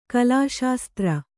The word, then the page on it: ಕಲಾಶಾಸ್ತ್ರ: kalāśāstra; Kannada